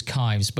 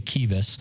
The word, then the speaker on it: Kives: It's spelt K-I-V-E-S. So you would think it was Kives, but it's actually Kives.